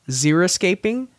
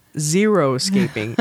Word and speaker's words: xeriscaping: So over the past decade, we've heard a lot about xeriscaping, not zeroscaping. I used to think it was zeroscaping, and I know a lot of people pronounce it that way.